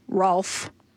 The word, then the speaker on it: Ralph: And I was like, Ralph and golf don't rhyme. And then I thought, well, that's just my Ontario A speaking. Because if you actually pronounce it Rolf and golf, then it works.